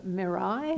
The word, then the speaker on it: Mirai: It's not that far off as that of Mirai. Mm-hmm. Is that the right way to say it? Yeah.